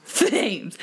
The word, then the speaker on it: Thames: Oh, River Thames. That's not, you said Thames. – Thames. She said, don't pronounce the TH.